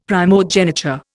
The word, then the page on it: primogeniture: Indian